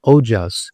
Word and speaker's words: ojas: Immunity is also very much connected to a substance that we call ojas in Ayurveda. It's spelled O-J-A-S.